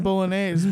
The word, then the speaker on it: bolognese: Fucking bolognese, bitch. – You owe me a bolognese bologna sandwich. – I love that. I love the silent G. The silent G is solid.